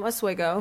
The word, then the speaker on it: Oswego: So what's it like, though, in Oswego? Like, is it like, or did I say that right? – Yes. – Is it Oswego or Oswego? – No, Oswego. – Oswego.